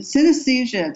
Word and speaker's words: synesthesia: synesthesia. – Yeah, synesthesia.